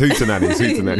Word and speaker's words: Hootenanny: Is that how you pronounce it? – Brixton. Hootoots. Yep. No, it's Hootenanny.